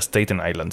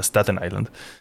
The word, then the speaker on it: Staten Island: Entonces, bueno, vi una película que se llama The King of Staten Island, Acabo de aprender eso, que no se pronuncia Staten Island, se pronuncia Staten Island.